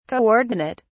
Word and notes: co-ordinate: British English

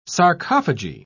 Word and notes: sarcophagy: British English

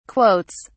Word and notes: quotes: British English

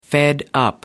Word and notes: fed up: American English

